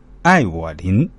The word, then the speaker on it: Evelyn: 张子林女儿英文名 Evelyn，怎么念？来跟我一起念“ 爱我林”，当然这是音译的。